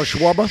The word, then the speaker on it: Schwarber: But Sale and Schwarber, they're close to coming back. I know. How do you pronounce his name? – Kyle Schwarber. – Schwarber.